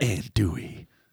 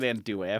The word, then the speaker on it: Andouille: Andouille. – Yeah. Thank you for pronouncing it Andouille. I've had close friends of mine call Andouille recently, and we had a long talk.